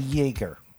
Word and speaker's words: Yeager: First up on the mix is our buddy Billy Yeager. I think that's how you say it. Like the Jägermeister, right? – Yeah, like the Jägerbomb.